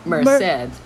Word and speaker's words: Merced: Merced。 – Merced，他在一家草莓苗公司工作。